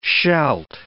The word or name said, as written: shalt